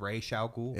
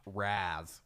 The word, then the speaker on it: Ra's al Ghul: It is Ra's al Ghul. – It is Ra's al Ghul. – Not Raz. – Not Raz Al Ghul.